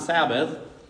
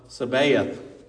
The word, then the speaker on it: Sabaoth: Spent all day yesterday trying to learn how to pronounce it correctly, because it is not Sabbath, but it's the Lord of Sabaoth.